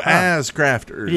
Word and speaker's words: AZ Crafters: Speaking of butt grabbing, AZ Crafter says, "The AZ in AZ Crafters is pronounced as in Diaz." – So as crafters.